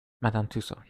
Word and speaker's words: Tussaud: that how you say it, Tussaud? – Madame Tussaud, yes.